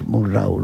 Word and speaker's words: Murnau: iban a ver cómo rodaba Murnau, o sea, lo del desembarco de Murnau es apabullante y su influencia hasta ahora mismo. – Como dirías tú, Wilhelm Friedrich Murnau, ¿no? – Murnau.